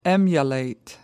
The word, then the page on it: emulate: American English